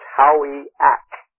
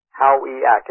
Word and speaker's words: Howayeck: His last name is spelled H-O-W-A-Y-E-C-K. And the way that he taught me how to pronounce it is if you swap the A and the E, in their, not the way, I mean, just the way it's spelled, it's Howiak. Howiak. It's Native American Indian. H-O-W-A-Y E-C-K, Dr. Ken Howiak.